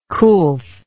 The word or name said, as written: coolth